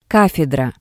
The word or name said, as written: kafedra